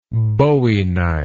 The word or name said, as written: bowie knife